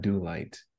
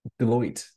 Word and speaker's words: Deloitte: It's not. It's not Do Light. It's um. I always say their name wrong. Starts with a D. I'm not familiar. Deloitte. There it is.